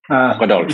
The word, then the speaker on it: Rodolphe: In French, we would say Rodolphe. – Rodolphe.